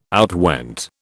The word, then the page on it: outwent: British English